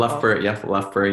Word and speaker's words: Loughborough: Loughborough University. I'm sorry, my pronunciation is terrible. – Loughborough University.